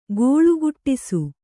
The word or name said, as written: ಗೋಳುಗುಟ್ಟಿಸು